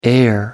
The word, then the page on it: heir: American English